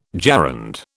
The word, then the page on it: gerund: British English